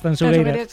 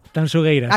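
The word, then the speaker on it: Tanxugueiras: A ver, repítemelo como tengo que pronunciarlo. – Tanchugueiras. – Sí, esto me lo corrige guste mucho. Digo, mira que yo siempre pronuncio. A ver, otra vez, dímelo. – Tanchugueiras. – Acaba de deshacerse la manifestación a las puertas de la Gran Vía, de lo bien que lo has dicho.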